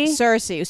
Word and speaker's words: Cersei: No, not Sensei, but Sorshi. – Oh, Cersei? – Cersei. So we open up with Cersei. I'm saying her name wrong, but and so I was like, okay, well, we're gonna be taken through her world, and I'm fine with that.